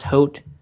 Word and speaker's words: haute: Is it Haute? Is it pronounced haute? You know that, Doug? You're fashion. – I have no idea. Not a clue. – Okay, I'm looking it up, and it looks like it is haute.